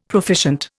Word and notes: proficient: Indian

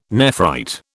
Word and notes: nephrite: British English

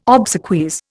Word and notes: obsequies: Indian

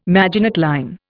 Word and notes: Maginot line: Indian

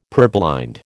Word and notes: purblind: American English

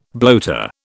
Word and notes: bloater: British English

